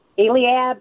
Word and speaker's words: Eliab: Eliab, That is correct.